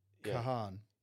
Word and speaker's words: Kahan: Kahan.